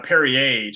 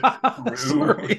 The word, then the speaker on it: Perrier: So she had French fries and French bread, and she had Perrier to drink, and she pronounced it Peru.